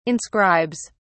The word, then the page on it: inscribes: British English